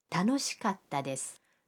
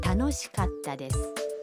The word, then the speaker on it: 楽しかったです: 楽しいです는 楽しかったです. – 즐거웠어요가 됩니다. – 갔다는 카와 타 사이에 한 박자를 넣어야 합니다. – 그럼 여러분도 따라서 발음해 보세요. – 楽しかったです.